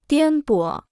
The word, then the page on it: 颠簸: diān bǒ